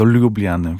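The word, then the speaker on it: Ljubljana: Czy to z Lublany do Klagenfurtu, czy z Klagenfurtu do Lublany, w zależności do Lubliany właśnie, bo ja tutaj też naciskam na tę słoweńską wymowę ze swojej strony.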